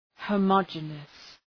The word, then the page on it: homogeneous: ,həʋmə’dʒınıəs